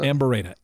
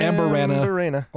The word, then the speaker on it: Amburana: Is that how you pronounce it? – Amburana. A-M-B-U-R-A-N-A. I've heard it Amburana. – Or Amburana, but I'm assuming it's one or the other.